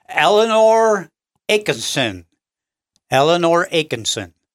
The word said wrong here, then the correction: Atkinson: Am I pronouncing that right? – Atkinson. – Atkinson. – Yeah. Eleanor Atkinson, I would pronounce that.